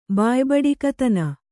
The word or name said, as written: ಬಾಯಿಬಡಿಕತನ